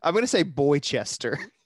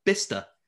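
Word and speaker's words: Bicester: I mean, it can't be the obvious pronunciation, right? So I'm going to say Boychester. – Boychester. And I'm saying Bitchester. Yes. – So we've got Boychester and Bitchester. The correct answer is Bister.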